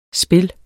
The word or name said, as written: spil